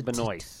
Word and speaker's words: Benoist: No, there is a T, but you don't pronounce it. So she's Melissa Benoist.